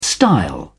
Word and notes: style: British English

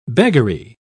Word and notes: beggary: British English

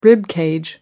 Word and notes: rib cage: British English

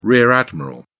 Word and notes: rear admiral: British English

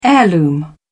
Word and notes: heirloom: British English

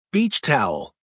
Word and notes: beach towel: American English

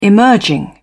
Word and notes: emerging: British English

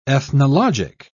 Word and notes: ethnologic: British English

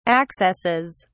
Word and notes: accesses: British English

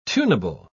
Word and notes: tunable: British English

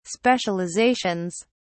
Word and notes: specializations: British English

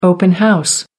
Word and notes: open house: American English

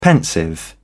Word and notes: pensive: British English

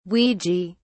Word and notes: ouija: British English

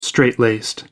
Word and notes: strait laced: American English

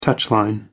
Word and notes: touchline: American English

